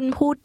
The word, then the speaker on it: พูด: คุณพูดได้ค่ะคุณพูดได้ค่ะ Literally, she said, You speak can. First, the word speak, which is pronounced with a falling tone.